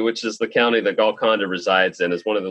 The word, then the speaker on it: Golconda: Golconda.